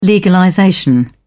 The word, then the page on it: legalization: British English